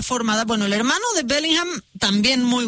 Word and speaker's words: Bellingham: Ya quedamos que es Bellingham.